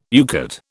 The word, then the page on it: UCATT: British English